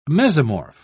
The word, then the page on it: mesomorph: British English